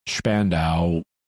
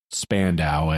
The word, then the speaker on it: Spandau: In German, the name is pronounced Spandau. In English, it's pronounced Spandau, and you may hear me say it both ways.